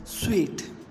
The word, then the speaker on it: suite: Zoology, Z-O-O-L-O-G-Y, zoology, which is correctly pronounced as zoology, zoology. Suit, S-U-I-T-E, suit, which is correctly pronounced as sweet. Genre, G-E-N-R-E, genre or genre, which is correctly pronounced as genre. Tomb, T-O-M-B, tomb, which is correctly pronounced as tomb.